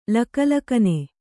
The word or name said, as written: ಲಕಲಕನೆ